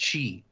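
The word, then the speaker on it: Chi (χ): Oh, it's so the X apparently is pronounced Chi. So it's Kingdom Hearts Chi.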